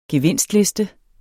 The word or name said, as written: gevinstliste